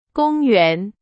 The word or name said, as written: 公元